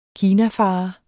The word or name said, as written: kinafarer